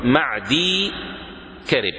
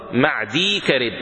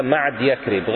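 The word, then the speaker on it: معديكرب: وعن المقدام بن معدي كرب رضي الله تعالى عنه، هذا والد المقدام رضي الله تعالى عنه، اسمه معدي كرب، تكتب الياء مع الكرب، يعني تكتب ميم عين دال ثم ياء كاف راء باء، وتلفظ معدي كرب، فبعضهم يقرأها معدي كرب، غلط، معدي كرب،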